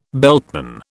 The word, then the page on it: beltman: British English